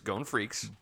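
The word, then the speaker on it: Freecss: Gon Freeze from Hunter x Hunter or Eren Yeager from Attack on Titan. – It's Gon Freaks.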